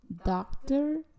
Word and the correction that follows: daughter: Daughter, exacto.